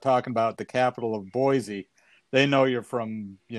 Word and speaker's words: Boise: But it's not really Boise. People in Idaho call it Boise. It's a soft C sound, and it's not a Z sound.